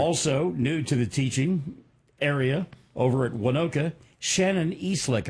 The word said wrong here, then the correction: Eslick: Am I saying that correctly? Eastlick, E-S-L-I-C-K? – It's Eslick.